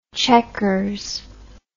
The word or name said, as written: chequers